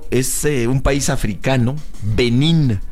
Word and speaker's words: Benín: Benín, acento en la I. Benín.